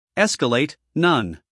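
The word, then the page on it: escalate: uk ˈeskəleɪt; en-us ˈeskəleɪt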